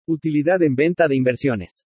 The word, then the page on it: UTILIDAD EN VENTA DE INVERSIONES: spanish